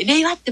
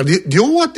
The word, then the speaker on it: 令和: だから言われる前に、令和っても発音しました。一番とか思い。 – あらららら。 – 最初ね、令和なのか、両和って読ませんのかと、ちょっと、どっちなんだろうと思ったんですけど、令和でしたね。